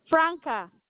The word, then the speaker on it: Franka: Franca. – How do you spell that? – F-R-A-N-K-A. – S-R-A.